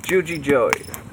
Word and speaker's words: JugiJoey: I'm joined with Winters Victor, Swack, Mithrin Deer for the second time, and today we have a special guest, JugiJoey.